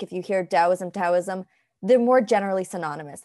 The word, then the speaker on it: Taoism: And at the end of the day, they're pronounced the same with the da-da sound. – The way Taoism. We would say it sounds more like a D, Taoism, yes.